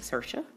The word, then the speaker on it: Saoirse: Now, Saoirse is S A O I R S E, but you pronounce it Sersha.